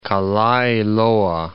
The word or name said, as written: Kalaeloa Point